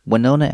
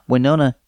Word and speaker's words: Winona: It's a small settlement east of Flagstaff and might have been forgotten if not for the lyric, Don't Forget Winona, written to rhyme with Flagstaff, Arizona.